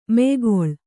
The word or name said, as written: ಮೆಯ್ಗೊಳ್